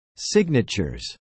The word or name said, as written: signatures